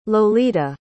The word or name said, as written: lolita